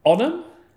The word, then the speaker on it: Onam: It's spelt O-N-A-M. Onam? – Onam. – I got it correct. I got the nod of approval.